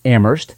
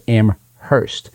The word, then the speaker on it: Amherst: In Massachusetts, they pronounce it Amherst. In New Hampshire, we pronounce it Amherst.